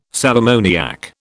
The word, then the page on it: sal ammoniac: British English